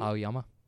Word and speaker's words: Aoyama: Aoyama.